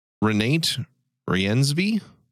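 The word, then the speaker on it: Renate Reinsve: Renate Riensby? – Yeah, that's exactly how I'd say it.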